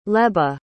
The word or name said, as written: leba